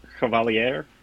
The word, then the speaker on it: Chevalier: This is a piece of music history I knew nothing about, and I'm excited to hear your thoughts on, is it Chevalier? – I believe, if I'm getting the French pronunciation right, it's Chevalier or Chevalier.